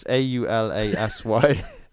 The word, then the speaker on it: Aulasy: Chapter 5 Boats Against the Current, holding on in the rush of the moment, and the word is Aulasy. Aulasy? – O L A S C. – Okay. – Is Y. – It's A U L A S Y.